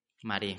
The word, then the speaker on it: Marais: Es un poble que es Marais o Marais, no sé cómo se pronunciaría la verdad. – En, en la llegó en Marí. – Marí, correcte.